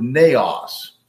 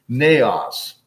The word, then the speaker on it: naos: The word used for temple here is the word naos. It's okay to learn a little Greek. Doesn't hurt, okay? Naos, okay? In Greek, N-E, omicron, short O-S, N-A-O-S, naos.